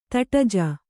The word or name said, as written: ತಟಜ